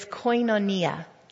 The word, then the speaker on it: koinonia: The word fellowship in the Greek is koinonia.